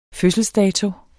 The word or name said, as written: fødselsdato